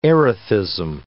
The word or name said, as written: erethism